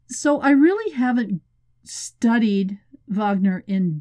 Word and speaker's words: Wagner: First of all, the Wagner Group, or you would pronounce it in Russian, Wagner, could really be straight out of fiction.